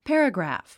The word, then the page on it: paragraph: pǽrəɡræ`f; パラグラァフ